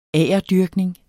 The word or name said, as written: agerdyrkning